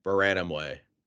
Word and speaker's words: Mburanumwe: And I listened to the pronunciation, and it's Baranamwe.